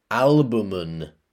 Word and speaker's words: albumen: The spelling, Y-O-L-K. The pronunciation, yolk. And then we have another noun, albumen. Let me spell that. A-L-B-U-M-E-N. Albumen. And then we have another noun, stench. S-T-E-N-C-H.